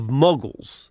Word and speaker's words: muggles: is the world of muggles, which rhymes with muddled.